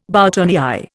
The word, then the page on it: boutonnière: Indian